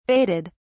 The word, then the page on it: baited: British English